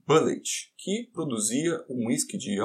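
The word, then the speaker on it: Bulleit: Um nome em que a pronúncia é bem parecida com bullet, ou bala, ou munição de pistola.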